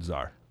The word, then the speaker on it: czar: I mean, I like the word czar. – I mean, think about I don't. It sounds super important. it does. It's got a silent C. There are no words that have silent C's.